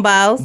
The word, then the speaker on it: Biles: Simone Biles. – Biles.